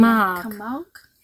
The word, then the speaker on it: Camargue: Wie wird das ausgesprochen? – Camargue.